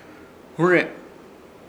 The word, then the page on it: hũre: Ishir; Chamacoco